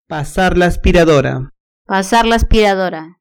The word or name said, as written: pasar la aspiradora